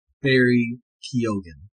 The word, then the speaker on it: Keoghan: Barry himself pronounced it Barry Keoghan.